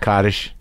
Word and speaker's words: Kaddish: Kaddish.